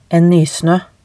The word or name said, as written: en nysnoe